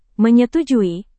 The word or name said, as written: menyetujui